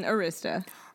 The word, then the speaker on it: Arista: Her record label, Arista, is that how you pronounce it? – Arista.